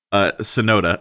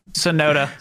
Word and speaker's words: Tsunoda: I'm sorry, how do you pronounce that? – Tussinato? – Tsunoda. – Tsunoda. – Yeah, silent T.